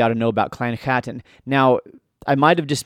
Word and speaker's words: Chattan: If you don't, if you're new to the subject, Clan Chattan. So I'm pronouncing that with a guttural ch because that's the I think the I'm just doing my best.